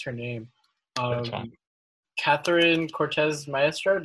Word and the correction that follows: Catherine Cortez Masto: Did I say that right? – Catherine Cortez Masto. I think it's. – Yeah, there we go.